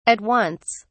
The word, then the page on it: at once: British English